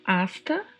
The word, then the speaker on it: hasta: Asta, na sua pronúncia, o H não tem som, assim como no português, então pronunciamos asta mesmo.